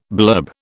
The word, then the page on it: blurb: British English